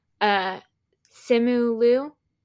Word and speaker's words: Simu Liu: Simu Liu, is mm-hmm. that right? – Yep.